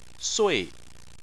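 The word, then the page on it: sui: tone 4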